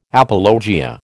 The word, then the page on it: apologia: American English